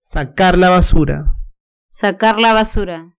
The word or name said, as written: sacar la basura